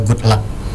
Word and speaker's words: GVDLVCK: Oke, jadi Good Luck-nya itu ya, sahabat suara, GVDLVCK ya. – Ejaan Indonesia Good Luck.